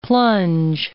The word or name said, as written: plunge